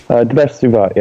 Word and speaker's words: Debesh Suvat: I hope I said that properly. – Debesh Suvat, yeah.